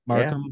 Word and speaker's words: Markham: How does a guy from, Markham? Is that how you say it? Markham, yeah. Ontario.